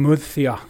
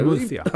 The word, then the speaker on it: Murcia: ligger eh, ut i regionen Murcia. Ja. Rätt uttal. My, mycket, mycket, mycket bra läspande ljud där. Murcia.